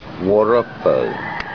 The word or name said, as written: wa